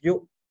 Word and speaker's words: Yud: The tenth letter of the Hebrew alphabet is called Yud. Rhymes with Mod, Yod, so Mod, so you know how to pronounce it. And has a sound of Y as in yes. So it's Yud, right?